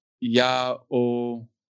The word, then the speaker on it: Yahweh: And so in this name, Yahweh, it has gone from Yahweh to then being spelt with the Adonai vowel markers. And so Yah-oh-wah, essentially.